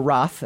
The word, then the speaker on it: Roht: Because or Roth as we Roth. Is Rot. – It Rot? Is Rot. It Rot? It's Rot. – R-O-H-T. – That's a silent H.